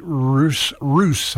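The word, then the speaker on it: Ruess: I've got Elton John's Little Genie and going to do a little bit of Pink with Nate Roos, I believe is how his name is pronounced.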